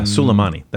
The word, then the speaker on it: Soleimani: Yeah, Soleimani. That's the proper pronunciation.